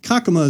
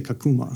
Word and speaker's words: Kakuma: I think that's why Kakuma is the correct way for us to say it, but they say Kakuma. – Yeah, Kakuma. And it's because of how they pronounce things.